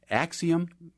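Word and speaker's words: Acxiom: You write in the book about Axiom.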